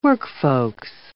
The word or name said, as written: workfolks